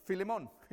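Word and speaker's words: Filemón: And actually, in Spanish, it's Filemon. It's easier. So at one point during the sermon, I go, Filemon, you know what I mean.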